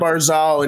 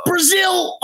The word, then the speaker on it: Barzal: He got to Matt Barzal, and he goes, Brazil.